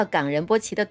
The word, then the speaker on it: 冈仁波齐: 说到冈仁波齐的读法呀，中间是要停顿的，也就是读作冈仁波齐。冈呢是雪山的意思，可以读成抗或者是冈都可以。